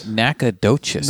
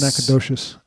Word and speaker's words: Nacogdoches: just signed up for Patreon, and his name is actually Derek out of, and he actually gives a pronunciation, Nacogdoches. – Texas. – Nacogdoches. – Nacogdoches. Outside of Doches. Nacogdoches. – Nacogdoches. Yeah. Sounds like braggadocious. – Yeah, sort of.